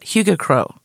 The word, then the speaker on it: Hyggekrog: Hygge-krog or Hygge-krog. Oh, that's how you say it. Hygge-krog. But it looks like Hyggekrog.